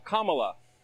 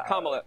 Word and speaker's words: Kamala: Her name is pronounced comma, like the punctuation mark, Kamala.